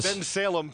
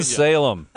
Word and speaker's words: Bensalem: Bensalem, Pennsylvania. – Bensalem.